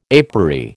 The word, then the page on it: apery: American English